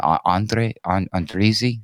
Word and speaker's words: Andrzej: Andrzej, there we go.